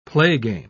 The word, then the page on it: playgame: British English